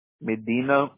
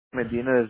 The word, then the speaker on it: Medina: Medina Villarreal. – All right. Could you spell that for me, please? I really want to make sure I got this corrected in our own system. Perfect. – Medina is M-E-D-I-N-A, and Villarreal is V-I-L-L-A-R-R-E-A-L. – V-I-L-L-A-R-R-E-A-L? – Yeah.